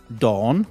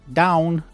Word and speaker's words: dawn: Okay, and the next one is dawn, which many people pronounce down, and it's wrong. Dawn, D-A-W-N.